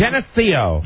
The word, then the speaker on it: Geneseo: Geneseo.